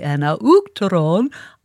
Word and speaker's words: Uachtaráin: And she wrote phonetically, Uachtaráin, O-O-K-T-A-R-E-I-N, Uachtaráin, agus Acharda.